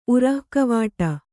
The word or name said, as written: ಉರಃಕವಾಟ